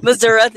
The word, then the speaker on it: Missouri: Missouri. Yes, that's what they call it. Therefore, that is the official pronunciation for the state.